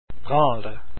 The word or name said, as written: bransle